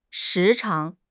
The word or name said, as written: 时常